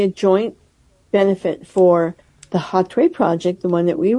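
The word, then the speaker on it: Hatuey: I say Hatwe, even though H is silent in Spanish. It's H-A-T as in Tom, U-E-Y, the name of the great indigenous leader from the 1500s.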